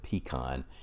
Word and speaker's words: pecan: As an aside, yes, I pronounce it pecan. If you're not from the South, in recent years there's been a large amount of debate on how you pronounce pecan. We're going to keep using pecan here.